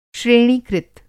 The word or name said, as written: श्रेणीकृत